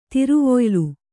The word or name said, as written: ತಿರುವೊಯ್ಲು